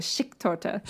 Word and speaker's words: Schichttorte: And what I wrote down for it phonetically was Schicktorte.